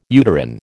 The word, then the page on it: uterine: American English